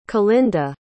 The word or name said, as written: calinda